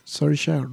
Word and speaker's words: Saoirse: Saoirse, Saoirse Ronan.